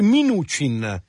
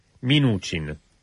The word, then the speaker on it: Mnuchin: Oggi ho imparato come si dice innanzitutto, si dice Mnuchin. – Mnuchin. Sì. – Si pronuncia così.